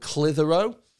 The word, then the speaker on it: Clitheroe: There's a place in, not far from here, actually, about an hour away, called Clitheroe. And it's spelled Clitheroe. And so it sounds an awful lot like Clit Hero.